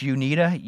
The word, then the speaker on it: Uneeda: and I think it's pronounced Unida, U-N-E-E-D-A. – That's how I'd pronounce it.